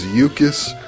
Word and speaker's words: Jukkis: He's from Finland, so that it starts with a J, but it's kind of a Y sound. J-U-K-K-I-S. and his last name is Uttila, U-O-T-I-L-A, Yukis Uttila.